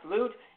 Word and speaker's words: Loupe: It's spelled L-O-U-P-E, pronounced Loot,